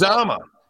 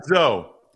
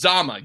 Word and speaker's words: Uzomah: I just Yazama. Yazama. Okay. Yazama. Za, uh, not Zo. It's Zama. Yazama. Um, and then uh, Samaje Perine actually got a target.